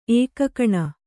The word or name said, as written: ಏಕಾಕಿನಿ